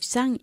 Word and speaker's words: sung: Muy importante bajar la mandíbula, tal y como lo hiciste tú, mm-hmm. a la hora de pronunciar el participio del verbo to sing. Okay, sung.